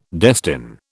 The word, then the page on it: destine: British English